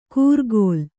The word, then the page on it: ಕೂರ್ಗೋಲ್: kūrgōl